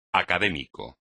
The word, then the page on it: académico: espagnol